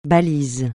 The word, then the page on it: balise: francais